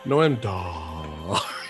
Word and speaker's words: Noam Dar: I just wish that Matt Nowak, when he does or even talks about, like he did in this one, about Noam Dar, I wish he would say it correctly, though. I want to hear Matt say, Noam Dar.